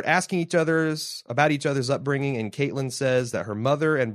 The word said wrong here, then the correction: Catelyn: Brienne, uh, wait, sorry, Catelyn asks about her mother. Let me pronounce her name correctly first.